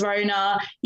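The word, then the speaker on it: Rhona: Yes, my name is Rona.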